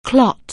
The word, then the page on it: clot: anglais